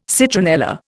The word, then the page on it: citronella: Indian